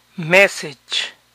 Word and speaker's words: message: Mensaje, message, message. El final de estas palabras de dos o más sílabas es itch. Message, mileage, image.